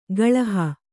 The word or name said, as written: ಗಳುಹು